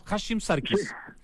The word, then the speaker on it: Hashim Sarkis: O sea, profundo, poético, pero también práctico al mismo tiempo esta exposición de Hashim Sarkis, ahí está bien pronunciado, ¿no? Hashim Sarkis. Yo lo decía al revés, ya, ok, Hashim Sarkis decía yo.